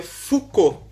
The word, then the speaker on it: Foucault: Se escreve Focaut, mas como é francês, se pronuncia Foucault.